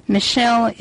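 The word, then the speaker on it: Michel: Michelle is an Englishman. M-I-C-H-E-L-E is how he spells his first name. When I first saw it, I thought it was Michael. But it's pronounced Michelle, and Faber is F as in farsight, F-A-B as in boy, E-R.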